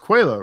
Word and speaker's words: Coelho: It's pronounced Coelho, right? Because that's the Portuguese pronunciation.